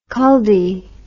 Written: culdee

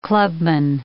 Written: clubman

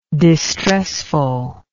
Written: distressfulness

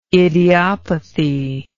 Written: idiopathy

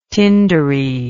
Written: tindery

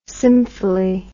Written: symphily

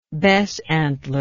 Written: besantler

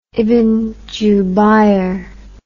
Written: ibn jubayr

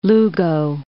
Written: Lugo

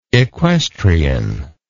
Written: equestrianism

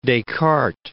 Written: Descartes

